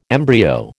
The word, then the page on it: embryo: American English